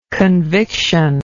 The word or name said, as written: convictional